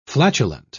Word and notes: flatulent: British English

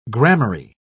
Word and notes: gramarye: British English

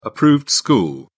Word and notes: approved school: British English

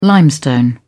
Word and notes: limestone: British English

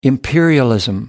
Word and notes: imperialism: American English

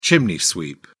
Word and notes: chimney sweep: British English

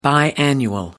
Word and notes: biannual: American English

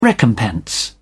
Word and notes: recompense: British English

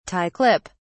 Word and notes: tie clip: British English

